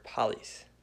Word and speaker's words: polys: but it's the same Greek word polys. Polys, I think, is how you say it. It's P-O-L-Y-S.